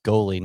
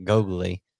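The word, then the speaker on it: Goli: He's like, you know, it's pronounced goalie, not gogly.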